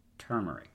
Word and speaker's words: turmeric: But it's turmeric, right? It's like you're barely opening your mouth. You pronounce the middle R, right? Or the first R? Turmeric.